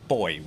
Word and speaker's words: buoy: How do you say it? Buoy. Boy. We boy. Americans yeah. say buoy. – Yeah. The, every boat has those safety buoys and uh, life jackets. – Somebody, so you just. Sorry. Buoy. – Yeah, Americans say buoy. Yes.